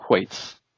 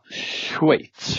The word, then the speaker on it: Schweiz: Eh, hur man uttalar Schweiz. Inte som eh, Gustaf Fridolin då, Schweiz. Utan det uttalar man som, som, som jag säger, Schweiz, Schweiz. Schweiz.